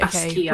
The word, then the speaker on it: Basquiat: Basquiat.